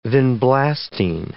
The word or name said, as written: vinblastine